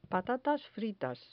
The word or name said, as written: patatas fritas